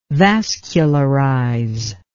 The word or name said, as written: vascularize